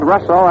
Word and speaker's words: Russo: For the Yankees, as they go afield, attendant Marius Russell, name is spelled R-U-S-S-O, and as Marius himself says, he wishes it called Russell and not Russo. Russell on the mound.